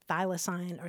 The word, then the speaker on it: thylacine: Now, that's an extinct marsupial that's also known as the Tasmanian tiger, and that's spelled T-H-Y-L-A-C-I-N-E. It's pronounced thylacine or thylacine.